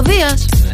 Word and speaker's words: Δίας: Ο Δίας. – Ναι, ο Δίας.